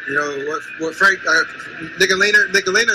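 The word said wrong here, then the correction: Ntilikina: He definitely said Nicolina. Now, clearly, that's not his last name. His last name is Nilakina.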